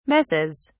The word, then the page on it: messes: British English